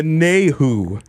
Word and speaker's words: Nayhoo: It's the Nahu. Okay. It's spelled N-A-Y-H-O-O.